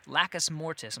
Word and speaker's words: Lacus Mortis: And let's investigate just some of the instruments on the Astrobotic lander that's going to be at, I think it's Lacus Mortis. I'm sorry if I'm pronouncing that wrong, but some of the instruments going there. – That's correct. You pronounced it correctly.